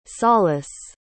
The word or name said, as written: salas